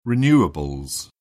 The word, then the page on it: renewables: British English